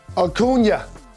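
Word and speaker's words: Acuña: Acuna. If I'm pronouncing it correct or incorrect. – Acuna.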